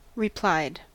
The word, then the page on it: replied: American English